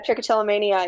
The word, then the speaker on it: trichotillomania: And I'll pronounce this wrong, but trichotillomania? – Yeah, trichotillomania.